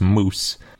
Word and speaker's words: mjosi: So specifically, the stegosaurus in this area is called Stegosaurus moose. And if you read it, the moose part is spelled M-J-O-S-I.